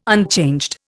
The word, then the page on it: unchanged: Indian